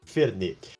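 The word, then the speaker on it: Fernet: E para quem não conhece Fernê, Fernê se escreve Fernete. Então é arroba Fernetebola no Twitter e arroba Fernetebola no Instagram. Mas se pronuncia Fernê.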